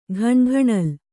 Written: ಘಣಘಣಲ್

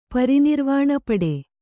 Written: ಪರಿನಿರ್ವಾಣಪಡೆ

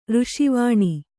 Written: ಋಷಿವಾಣಿ

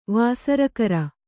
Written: ವಾಸರಕರ

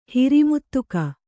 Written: ಹಿರಿಮುತ್ತುಕ

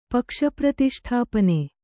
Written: ಪಕ್ಷಪ್ರತಿಷ್ಠಾಪನೆ

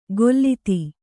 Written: ಗೊಲ್ಲಿತಿ

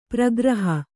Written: ಪ್ರಗ್ರಹ

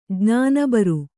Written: ಜ್ಞಾನಬರು

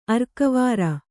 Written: ಅರ್ಕವಾರ

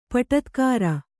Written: ಪಟತ್ಕಾರ

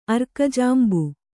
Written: ಅರ್ಕಜಾಂಬು

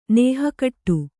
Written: ನೇಹಕಟ್ಟು

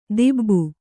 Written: ತೀಬು

